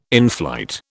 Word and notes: inflight: British English

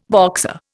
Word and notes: Boxer: Indian

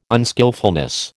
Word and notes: unskilfulness: American English

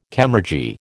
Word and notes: chemurgy: American English

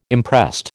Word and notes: imprest: American English

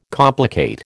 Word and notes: complicate: American English

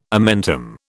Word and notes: omentum: British English

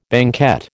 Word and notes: banquette: American English

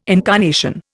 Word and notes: incarnation: Indian

